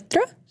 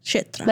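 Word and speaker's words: Kshetra (क्षेत्र): Arjuna's asking about prakriti, which is nature, purusha, the enjoyer, setra. How you pronounce Kshetra? – Kshetra. – Like right? Kurukshetra. Exactly.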